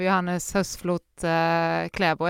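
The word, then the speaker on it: Johannes Høsflot Klæbo: Jag tar det som att det förmodligen kommer kanske bli en tredje gången gilt här för Johannes Höstflot Kläbo. Är det så man uttalar det?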